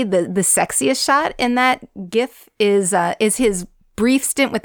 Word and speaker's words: GIF: Yeah, and you got to catch out that GIF. I always say that wrong. GIF. Damn it.